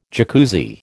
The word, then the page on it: Jacuzzi: American English